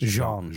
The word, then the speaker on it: Jean: I shouldn't say his name is John. It's not John. It's Jean.